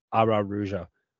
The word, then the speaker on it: Araújo: We're putting that extra syllable in there. Araújo.